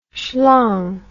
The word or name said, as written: schlong